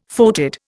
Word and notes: foetid: Indian

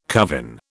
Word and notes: covin: British English

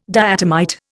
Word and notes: diatomite: Indian

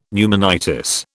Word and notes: pneumonitis: British English

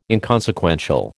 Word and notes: inconsequential: American English

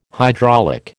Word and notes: hydraulic: American English

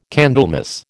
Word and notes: Candlemas: American English

